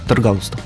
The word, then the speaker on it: Таргалустов: Это Сергей Хизев, а, Вадим Таргалустов и Андрей Береснев, да? И, я надеюсь, что я правильно везде ударение расставил. – Таргалустов. А, Таргалустов, все.